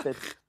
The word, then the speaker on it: Fett: Ah, Fett. Boba. C'est comme la fête.